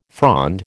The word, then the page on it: frond: American English